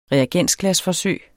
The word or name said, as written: REAGENSGLAS